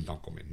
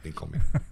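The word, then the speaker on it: Dunkleman: We went from Emmys to Brian Dinkelman. Brian the Dink Dunkelman. It's actually Brian Dunkelman, not Dinkelman.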